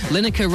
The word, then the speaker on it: Lineker: Lineker rhymes with spinnaker, which is a type of sail commonly found on a yacht.